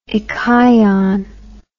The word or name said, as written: echion